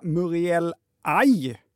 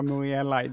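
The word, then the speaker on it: murieliae: När ett ord innehåller kombinationen AE ska det uttalas som AI. Kan vara bra att komma ihåg när ni ska prata växter nästa gång. Hälsningar Joakim. Mm. Tack! Då ska den här sortens bambu alltså uttalas Fagesia muriel AI.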